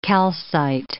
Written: calcite

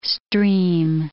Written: stream

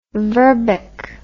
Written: verbeck

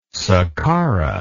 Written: saqqara

